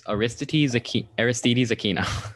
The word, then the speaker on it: Aristides Aquino: Wait, I need the pronunciation. – It's Aristides Aquino.